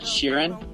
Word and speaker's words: Sheeran: My pick of the week is a song called "Drunk," and it's by an artist named Ed Sirin. – I'm still on the call. It's Ed Sheeran. You're pronouncing it pretty weird.